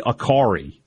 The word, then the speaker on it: Akari: I believe it's Kelly Akari. It's like Atari with a K.